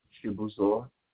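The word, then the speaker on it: Chibuzor: all right, so the name is Chibuzo Agwonya, and this is the correct...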